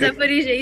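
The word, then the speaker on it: Zaporizhzhia: You have a very good pronunciation of my hometown, actually. – Thank you. – Zaporizhia, you said it very good.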